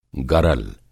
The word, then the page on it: गरल: Hindi